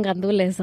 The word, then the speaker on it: gandules: Arroz con gandules, 100%. Gandules, that's how you spell it. That's how you say it.